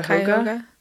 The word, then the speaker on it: Cuyahoga: Anyone with information about Aliza's case is asked to call Crime Stoppers of, and I'm going to say this wrong, Cuyahoga County, Cuyahoga. How do you say that? – Cuyahoga. Yeah.